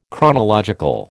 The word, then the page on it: chronological: American English